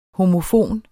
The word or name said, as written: homofon